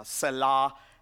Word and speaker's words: Selah: We are taking a journey through selected psalms this summer as we're in this series that we've entitled Selah, or the exact pronunciation is Selah.